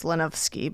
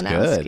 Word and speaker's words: Longuski: And technically our last name is pronounced Lonowski, but we sort of Americanized it. Someone before me Americanized it. – That's to Lonowski.